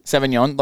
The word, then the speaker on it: Sauvignon: What does Sauvignon, am I pronouncing that right? – Sauvignon.